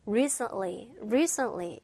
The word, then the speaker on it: recently: 네, 중간에 있는 t 발음 있잖아. 얘가 앞에 있는 n과 뒤에 있는 l 사이에 들어가면서 recently 이런 식으로 없었던 것처럼 귀에 들리진 않았어요.